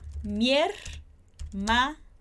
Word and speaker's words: hermana: Então, a gente tem I, E. A gente vai falar mi-er-ma-na, perfeito?